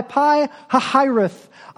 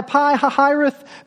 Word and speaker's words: Pi Hahiroth: Order the Israelites to turn back and camp by Pi Hahiroth. I just need to say every time I say that, I am proud of myself for pronouncing it right. I practiced a lot.